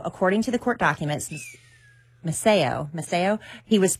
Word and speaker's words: Maceo: How do you say M-A-C-E-O? – Maceo? – Maceo.